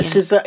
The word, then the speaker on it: SZA: He finds focusing on his wife helps, and they begin dancing to a classical version of Saw's Nobody Gets Me. And again, it's a song I've never heard of, because old. – That's why you pronounce SZA wrong. – Yeah. SZA, yeah, okay.